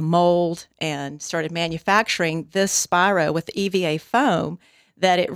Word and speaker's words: Spiro: And it's Spiro, like Spiro Agnew, the vice president who resigned in disgrace under Richard Milhouse.